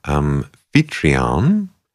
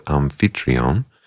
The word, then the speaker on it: Amphitryon: Incidental music for a play called Amphitryon in the British pronunciation, or Amphitryon in French, although it was a British play. So yeah, we'll go with Amphitryon.